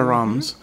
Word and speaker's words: Reims: Pues eh, básicamente el champán se produce en un valle eh, que tiene como centro o centro de operaciones, por decir así, la ciudad de Reims, que se pronuncia Rams.